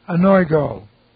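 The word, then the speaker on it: Anoigo (ἀνοίγω): This is an aorist active subjunctive of the verb anoigo. Okay, this is A-N-O-I-G, long O. Anoigo.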